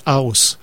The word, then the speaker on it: HUIS: Et il semblerait que HUIS, faudrait le prononcer House, c'est ce que Michel me disait cet après-midi. Mais comme on est des francophones, on va dire oui, tout simplement.